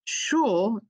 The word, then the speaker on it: Sheol: And they send us to this place called Sheol, pronounced Sheol in Hebrew, which means place of the dead.